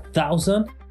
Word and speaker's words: thousand: Recordemos que la pronunciación de la palabra thousand tiene un fonema que nosotros en Colombia no tenemos y es TH. Colocando la lengua entre los dientes, como los españoles que en vez de decir zapato, como nosotros decimos, dicen zapato.